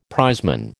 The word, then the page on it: prizeman: American English